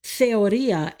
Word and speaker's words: theoria: So in Antioch, the spiritual insight was found through theoria, and that's a Greek word. I'll spell it for you. T-H-E-O-R-I-A.